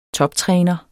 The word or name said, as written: toptræner